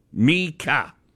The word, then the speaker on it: Mika: Okay, so here's how it is spelled phonetically. Mika. Capital M-E-E-K dash K-A-H. Mika. – Muurinen. – Here's how Muurinen is spelled. Capital M-O-O, as in moo, like a cow, dash R-E-H dash N-E-N.